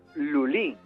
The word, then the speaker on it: Lully: Bueno, y es que con esta música introduzco al compositor de hoy, que es Lully, Jean-Baptiste Lully. – Lully, para mí era Lully. – Lully. Bueno, claro, mal te lo enseñaron.